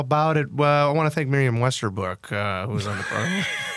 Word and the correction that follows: Westerplank: Did I say her name right? She was great. – Westerplank. – Miriam Westerplank.